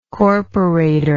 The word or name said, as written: corporator